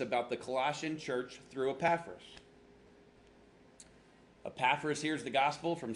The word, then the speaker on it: Epaphras: Next line, a man named Epaphras. I'll spell it for you. E-P-A-P-H-R-A-S. E-P-A-P-H-R-A-S.